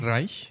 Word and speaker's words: Reisch: One of the writers was Walter Reisch. He was an Austrian writer. Did I pronounce that right? – Reisch, yeah. – Reisch, there you go.